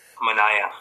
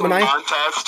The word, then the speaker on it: Manaea: But if they get an arm, yeah, to go with Manaea or Manaea, however you say his name, Manaea. Yeah. Is it Manaea? too. – Yeah, yeah.